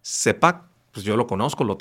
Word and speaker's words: CPAP: Se pronuncia CPAP. En la traducción correcta es CPAP.